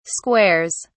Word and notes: squares: British English

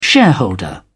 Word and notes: shareholder: British English

